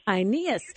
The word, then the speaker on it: Aeneas: I think I'm saying that wrong. How do you say it again? – It's like Aeneas. – Aeneas, who'd been paralyzed and bedridden for 8 years.